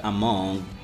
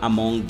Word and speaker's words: among: Então, a palavra among, ela se pronuncia, ela, it's spelled A-M-O-N G. Só que o detalhe é que essa letra G, G no final, você não vai falar among ou among, né? Não é um som de G, nem um som de G, ok?